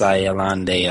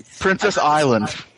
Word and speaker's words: Ayalande: They said Ayalande, which is the worst, the laziest pronunciation because it's just pronouncing it. That's not fair. You just read it. Like you can't just pronounce a word the way it's spelled. – Just don't be phonetic. That's lazy.